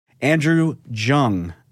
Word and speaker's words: Andrew Junge: Andrew Junge.